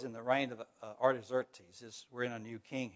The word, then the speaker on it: Artaxerxes: Artaxerxes, yes. I have the pronunciation in my outline, but I can't find it right now. But Artaxerxes is the king now.